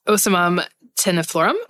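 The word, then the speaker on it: Ocimum tenuiflorum: I don't know if you've ever seen that, but it's, they always mispronounce this, but I believe it's Osimum tenuflorum. And it is used as an adaptogen.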